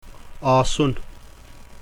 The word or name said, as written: aasun